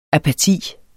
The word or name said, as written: apati